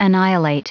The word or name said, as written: annihilate